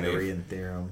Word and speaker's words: Pythagorean Theorem: Everything from the constructs, the way buildings are designed, from Solomon's Temple to the Great Pyramids to the Pythagorean Theorem. I don't know if I said that right, but that actually originated... – Pythagorean Theorem.